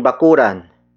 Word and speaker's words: bakuran: As a possession indicator, a good example would be ating bakuran. Ating bakuran. Ating bakuran.